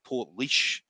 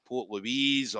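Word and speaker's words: Portlaoise: I was pronouncing Port Leash. I remember when Port Leash we first started, it was like Port Louise or Port, and it was always wrong.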